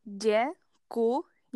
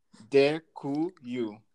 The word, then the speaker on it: Děkuju: Děkuju. – Děkuju.